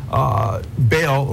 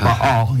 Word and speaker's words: Baal: And then Baal, or he says Bel, B-E-L, or Baal. He spells it B-A-A-L, how the Bible spells Baal or Baal, it's pronounced in Hebrew, which is the Canaanite god.